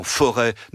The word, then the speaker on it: forêt: Les imprimeurs, qui sont des réformateurs, souvent protestants, disent le S devant T dans forêt ne se prononce plus depuis des siècles, on le remplace par un circonflexe qu'ils empruntent au grec où il a cette fonction.